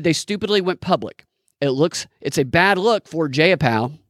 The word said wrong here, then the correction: Jayapal: I should look up how to pronounce her name real quick. Jay Appel. It's a bad look for Jayapal and those having signed this letter.